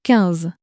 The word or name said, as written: quinze